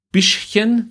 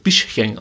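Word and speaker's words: Bišćan: Der Trainer der kroatischen U21, Igor Bischchen, wird da, glaube ich, ausgesprochen. Biskan geschrieben, aber ich glaube, es wird Bischchen ausgesprochen. Ähm, meinte, wir hatten hier alles völlig unter Kontrolle.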